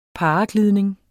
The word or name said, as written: paraglidning